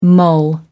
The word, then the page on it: mull: American English